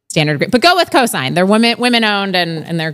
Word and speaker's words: Cosynd: And there's a great company, I should learn how to pronounce their name, but it's Cosigned. Cosynd, Cosynd.